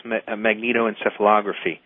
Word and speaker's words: magnetoencephalography: Yes, magnetoencephalography.